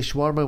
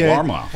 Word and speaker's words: shawarma: I'll say shawarma, whatever. – No, say it right, dude. – Okay. – Shawarma.